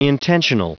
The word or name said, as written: intentional